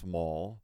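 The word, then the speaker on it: Mull: Yes, my last name is pronounced Mall, not Mool, Mool, like some people try to pronounce it. I'm not sure how you get the U-L-L wrong.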